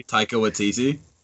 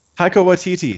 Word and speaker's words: Taika Waititi: Taika Waititi? – Taika Waititi.